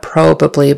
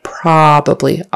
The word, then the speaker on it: probably: Make sure the accent is on the first syllable, probably, and that it's a short O for the O, probably, not probably, but probably, The ah sound, your tongue or your mouth opens like this, probably.